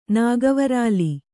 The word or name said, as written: ನಾಗವರಾಲಿ